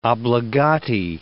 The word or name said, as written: obbligati